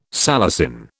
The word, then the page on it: salicin: British English